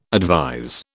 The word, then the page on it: advise: British English